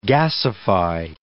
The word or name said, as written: gasify